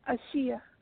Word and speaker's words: Ashia: My name is Ashia, calling from Georgia. – Hi. – Okay, can you say your name one more time? I didn't quite catch it. Sound like Lashia. – Oh. Yeah, Ashia.